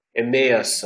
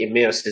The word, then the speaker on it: Emmaus: There are many ways of pronouncing this thing, honestly. Emmaus, or I think Emmaus is the way.